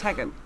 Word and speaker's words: Teigen: Yeah, but even because Chrissy Teigen, as everyone calls her, it's Tegan. – Even she was mispronouncing it, her own name.